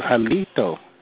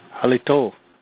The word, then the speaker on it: Halito: Instead, he pronounced it almost like you're saying burrito. He goes, Halito. That is not how you say hello in our language. Halito. And you say it like you mean it.